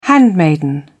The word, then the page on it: handmaiden: British English